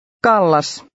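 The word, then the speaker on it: Callas: Here the C is pronounced like the English K. Read number 5. – Kallas.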